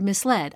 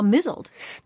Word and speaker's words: misled: Exactly, because I knew there was a word misled. But then I somehow also saw mizzled.